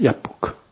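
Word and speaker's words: 야복크 (יבק): 약복을 히브리어로 야복크라고 합니다. 이것도 준비 안 했나요? 여기 있네. 야, 비읍이 이게 있으면 두 번으로 발음해라 이런 뜻이거든요. 그래서 야복크입니다. 야복크.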